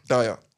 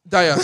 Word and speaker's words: Dier: Was ich jetzt sage ist, ähm, wenn der Vorstand, also wenn Bayern nicht schnell reagiert, jetzt äh, die, die Verpflichtung von Dier, oder wie man den ausspricht, ist da fest, Dyer. – Dyer, so, ja, sorry, ich, ich Eric meine, Dyer.